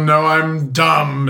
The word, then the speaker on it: Noem: Yeah, no money, no I'm so, no I'm dumb. That's how you pronounce her name.